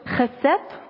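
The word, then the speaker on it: Chesed (חסד): Chet Samech Dalet, auf Deutsch Chesed wird es ausgesprochen.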